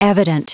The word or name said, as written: evident